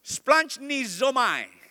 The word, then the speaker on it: splanchnizomai: In the Greek is, somebody try to read out with me, splanchnizomai, splanchnizomai. It's like splurging, you know, splunge. And the pronunciation itself also, I mean, with a lot of gutturals there. Splunge nizomai.